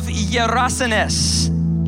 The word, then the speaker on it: Gerasenes: They came to the other side of the sea, to the country of Gerasenes.